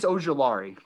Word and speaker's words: Ojulari: Azeez Ojulari. I hope I said that name correctly. I'm trying to get better with names, bro, with this pronunciation and podcasting. Am I good with that? – Azeeez Ojulari. – Ojulari. Close. Wow.